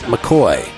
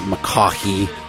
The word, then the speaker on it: McCoy: My guest for episode 49 is Scott McCoy. And yes, that is how it's pronounced. It's not McCaughey.